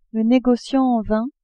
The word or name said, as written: negociant en vins